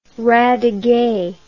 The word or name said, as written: radiguet